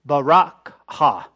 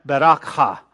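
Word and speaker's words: berakhah: It's Bechorah, Bechorah, B-E-K-H-O-R-H. And that's considered the birthright, okay? The blessing is spelled differently. It's B-E-R-A-K-H-A-H, Barakha. Right? Barakha, right?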